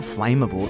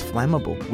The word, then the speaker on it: flammable: And then I was like, yeah, what about, she mispronounced flammable. She's called it flammable. And I was like, no, it's not flammable. It's flammable.